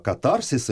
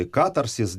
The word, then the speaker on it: Катарсис: Итак, «Осенний сон» — это песня из нового альбома «Катарсис» или «Катарсис». Делайте ударение, как вам нравится, и то, и другое правильно.